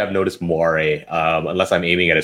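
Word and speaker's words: moiré: But when you're manually focusing, you can sometimes get what appears to be is it Moire? Moire? I can never quite remember how you pronounce it. Yeah, Moire.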